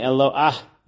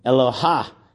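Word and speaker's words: elo'ah (אלוה): So many times people pronounce it with the hey at the end as if it's Eloha, as if they're actually saying, like they're in Hawaii and they're saying aloha. But in fact, it's elo'ah, which is a mapik hey, just like the word tapu'ach, where you wouldn't say tapucha when referring to an apple.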